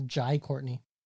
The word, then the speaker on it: Jai Courtney: Apparently, it's not pronounced Jay Courtney. You know that? It's Jai Courtney.